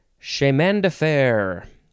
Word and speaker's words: chemin de fer: Chemin de fer.